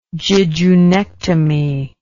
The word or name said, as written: jejunectomy